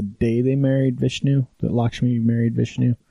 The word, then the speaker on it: Lakshmi: All right, so celebrate Lakshmi. I can't ever say the name right. That's the Lakshmi. Yes.